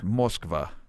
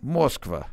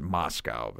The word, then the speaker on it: Moskva: It's Moskva. – How do you say it again? – Moskva. – Moskva. – Yeah. – Is that? – That's how we pronounce it, Moscow.